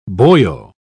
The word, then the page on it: boyo: British English